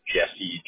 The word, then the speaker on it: Jessie: My first name is Jesse, J-E-S-S-I-E.